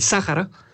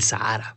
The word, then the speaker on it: Sahara: Pero bueno, habría que ver a fondo la pronunciación, pero aquí le vamos a decir el desierto del Sahara. Con eso de que la H no suena, pero bueno, cada quien que lo llame como guste.